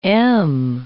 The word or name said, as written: m